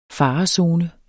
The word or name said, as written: Farezone